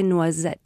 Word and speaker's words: noisette: And in French, that would be a café noisette.